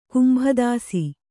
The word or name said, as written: ಕುಂಭದಾಸಿ